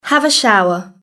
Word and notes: have a shower: British English

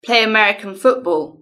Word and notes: play American football: British English

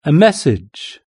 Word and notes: a message: British English

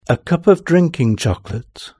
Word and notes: a cup of drinking chocolate: British English

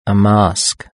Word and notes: a mask: British English

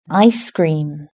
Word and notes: ice cream: British English

